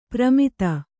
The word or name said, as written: ಪ್ರಮಿತ